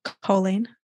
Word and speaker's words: choline: Choline? – Choline.